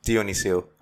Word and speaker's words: Dionyssiou: Dionyssiou.